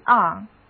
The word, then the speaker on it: lever: 我们先在 leave 这个词根上做文章，在它的后面加上 er 这个名词后缀表示物，那得到的这个单词就是 lever， lever。第一个字母 e 的读音是小口的 a，而字母组合一、二的读音是读短的嗯，所以一起来念一遍吧。